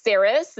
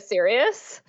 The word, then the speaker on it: Sirius: And I called Cirrus Cirrus instead of Sirius.